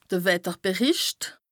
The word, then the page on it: de Wetterberìcht: Bas-Rhin; Strasbourg